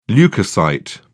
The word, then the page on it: leucocyte: British English